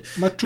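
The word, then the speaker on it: Masucci: Masuki, keine Ahnung, wie der ausgesprochen wird. – Matuki.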